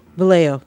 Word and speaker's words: Vallejo: Vallejo. – Vallejo? – Vallejo.